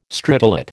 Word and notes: stridulate: American English

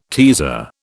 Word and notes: teaser: British English

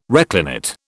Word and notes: reclinate: British English

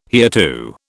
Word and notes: hereto: British English

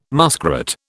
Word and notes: muskrat: British English

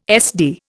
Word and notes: SD: Indian

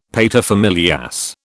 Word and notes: paterfamilias: British English

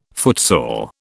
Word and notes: footsore: British English

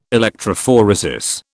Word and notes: electrophoresis: British English

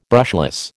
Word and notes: brushless: American English

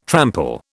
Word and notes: trample: British English

